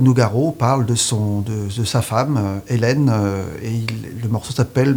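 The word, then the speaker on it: uilleann: La prononciation de notre instrument en Irlande se dit Hélène Pipe.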